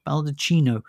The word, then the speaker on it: Baldacchino: The big standout here, though, for me, really, is Gabriella Balanchine. Sorry. Pronounce that wrong. Baldacchino. – Baldacchino.